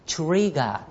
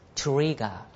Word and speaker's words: trigger: この spark を他の英語で言いますと trigger と言います。 trigger。 trigger です。 trigger。 trigger。 trigger。ね。はい。この R の発音大丈夫ですかね。舌がね、上にくっついてませんね。浮いてますね。